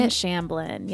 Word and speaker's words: Shamblin: Gwen Shamblin, yes.